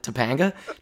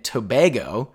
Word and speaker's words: Tobago: Let's hear you pronounce this. What'd you call it? Topanga? Trinidad and Topanga? – Yep, that's the one. – As you were trying to say, Mike, they're in Trinidad and Tobago.